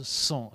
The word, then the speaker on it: sens: On le prononce sans à ce moment-là.